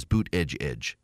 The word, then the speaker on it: Buttigieg: So the reason I say Buttigieg is because when he was running for president, that's what his campaign said, is that the way you pronounce his name is Buttigieg. It's Buttigieg, Pete Buttigieg.